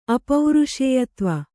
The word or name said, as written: ಅಪೌರುಷೇಯತ್ವ